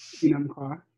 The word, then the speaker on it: Enumclaw: I hope I pronounced that correctly. – Enumclaw, yeah.